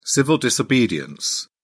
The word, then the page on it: civil disobedience: British English